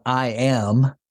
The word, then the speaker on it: Iam: His name is spelled I am, but is pronounced Iam.